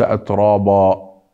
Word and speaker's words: أترابا: انظروا كيف قرأنا، ليس أترابا، أترابا، أترابا. الراء إذا نطقناها مفتوحة أو نطقناها لفظناها وبعدها حرف ألف لا بد أن تكون مفتوحة، فنأتي بالألف أيضا مفخمة، الألف تفخم، را را، هكذا نقول أترابا، أترابا.